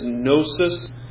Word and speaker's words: gnosis: You've probably learned at some point that a usual word for knowledge is gnosis, and when you spell that in our letter, you put a G, G-N-O-S-I-S, gnosis,